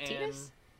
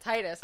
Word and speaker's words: Tidus: In Kingdom Hearts they pronounced it Tidus, and my whole life I had pronounced it Titus.